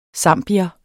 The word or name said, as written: zambier